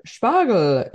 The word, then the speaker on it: Spargel: Spargel is how you say asparagus here. So like Spargel here.